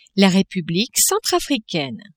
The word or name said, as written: Republique centrafricaine